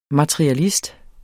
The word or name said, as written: materialist